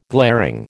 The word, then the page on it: glaring: American English